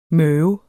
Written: møve

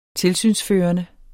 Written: tilsynsførende

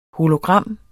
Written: hologram

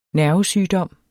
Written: nervesygdom

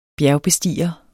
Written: bjergbestiger

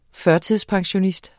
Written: pensionskunde

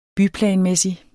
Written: byplanmæssig

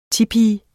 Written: tipi

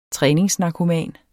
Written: træningsnarkoman

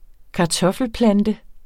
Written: kartoffelplante

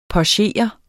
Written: pochere

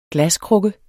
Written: glaskrukke

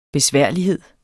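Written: besværlighed

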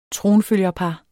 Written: tronfølgerpar

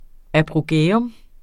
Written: apogæum

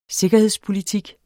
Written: sikkerhedspolitik

